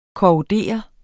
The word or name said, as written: korrodere